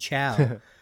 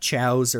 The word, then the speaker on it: Chao: these adorable little blobby water creatures called Chao, not Chows or cows or chaoses.